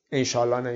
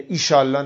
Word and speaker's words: انشاءالله: یعنی این انشاء اللهی که میگوییم، اولا درست بگیم تلفظشو، انشاءالله نگیم یا ایشالله نگیم. انشاءالله سه کلمه است، ان و شاء و الله، یعنی اگر خواست خدا باشد.